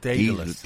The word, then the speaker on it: Daedalus: Oh, Daedalus, is that how we're? – That's how we pronounce it here. That's how I was told to pronounce it. – Oh, right, Daedalus.